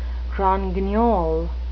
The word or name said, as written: grand guignol